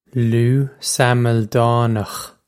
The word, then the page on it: Lugh Samildánach: Loo Sammil-dawnokh